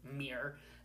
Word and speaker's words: miR: which is pronounced as MIR, and then you have a hyphen, and then the numerical or whatever designating a sequence number that they prescribe to it.